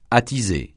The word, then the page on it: attiser: francais